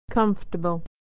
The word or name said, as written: comfortable